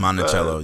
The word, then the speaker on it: Monticello: I couldn't help but think, like, we went out to, I feel like I always say this wrong, Monticello, we'll call it Monticello. It's like, it's Monticello, yeah.